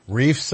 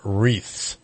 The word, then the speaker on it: wreaths: And they're putting, reefs up or wreaths up? – Well, it's Reefs Across America. – I think that's pronounced wreaths. – That's what I said.